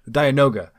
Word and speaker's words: Dianoga: So for A New Hope, we got the Diagondra. Did I say that right? I think I probably goofed that. Dianoga. – Yeah. – In episode 4, we had the Dianoga, which just something under the water that I can't see.